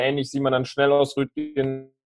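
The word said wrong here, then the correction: Röttgen: Rötchen wie Brötchen. – Okay, dann Rötchen.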